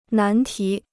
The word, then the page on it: 难题: nán tí